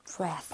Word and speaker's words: breath: breath. – 발음도 breathe가 아니라 breath. – breath.